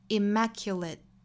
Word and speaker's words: immaculate: 后面的这个 immaculate 这个词应该是一个生词，所以大家跟着我一起来读一下这个词 immaculate。好，注意这个词的重读音节在第二个音节上。